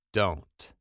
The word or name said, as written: dont